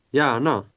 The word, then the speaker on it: gärna: Notice how a G followed by an A umlaut is pronounced in Swedish. Listen and repeat. – Ja, gärna.